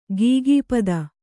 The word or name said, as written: ಖಗಪತಿ